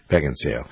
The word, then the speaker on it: Beckinsale: Beckinsale.